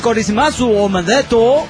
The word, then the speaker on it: Kurizumasu Omedeto: Bueno, espero estar pronunciándolo mínimamente cercano a como se dice en realidad. Kurizumasu, no, Kurizumasu. Kurizumasu Omedeto. – Ahí está.